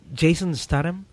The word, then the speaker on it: Jason Statham: So yeah, we got to see Jason Statham. Is that how you say it? – Yes.